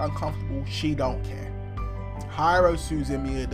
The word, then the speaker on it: Suzumiya: Her name is spelled H-A-R-U-H-I, and it's Suzumiya. So it's S-U-Z-U-M-I-Y-A. Hiro Suzumiya.